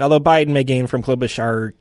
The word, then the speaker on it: Klobuchar: I don't think that what Klobuchar did worked. And I think said her name right. – Klobuchar.